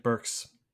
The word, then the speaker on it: Burks: A lot of people aren't used to the S being on the end of Burks because of like Dooney and Burke, the person.